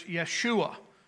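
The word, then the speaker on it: Yeshua (ישוע): In the Hebrew language, it is the same word as the name Joshua. And in their pronunciation, it is Yeshua.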